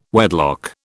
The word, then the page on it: wedlock: British English